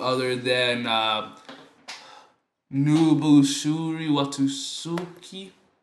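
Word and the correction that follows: Nobuhiro Watsuki: I can't pronounce it. – Nubuhiro Watsuki. – There you go.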